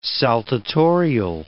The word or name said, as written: saltatorial